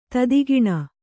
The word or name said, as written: ತೆತ್ತಗಣ್